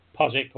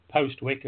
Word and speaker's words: Postwick: which was out in Postwick, or Postwick as it's spelt, in Norfolk.